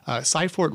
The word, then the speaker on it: Cyport: Well, when you talk about expanding, you recently purchased Cyport. Is that how you pronounce it? – Yes, Cyport.